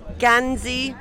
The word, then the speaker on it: Gansey: And how do you pronounce the Irish word for cardigan? – Gansey is the Irish word for sweater or cardigan.